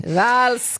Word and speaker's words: Valls: Valls, Catalonia.